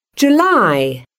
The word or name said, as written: July